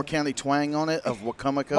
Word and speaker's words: Wicomico: I pronounce it wrong. I call it Wicomico. – It's Wicomico.